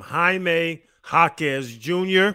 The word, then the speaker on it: Jaime Jaquez Jr.: Jaime Jaquez Jr. Very good.